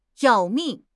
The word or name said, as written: 要命